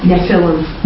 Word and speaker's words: Nephilim: How do you say it again? Nephilim? – Nephilim.